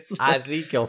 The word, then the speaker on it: Ezekiel: Well, I think they're going to know from the fact that you can't pronounce the name of the book of the Bible. – Ezekiel 5:8. Ezekiel 5:8. – No, Ezekiel. – Ezekiel 5:8.